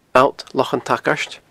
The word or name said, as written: Allt Lochan an t-Sagairt